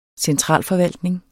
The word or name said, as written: centralforvaltning